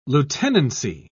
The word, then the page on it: lieutenancy: British English